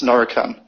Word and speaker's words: Norican: And that partner is it pronounced Norrison or Norican Group? – It's Norican.